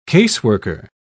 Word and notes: caseworker: British English

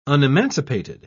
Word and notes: unemancipated: British English

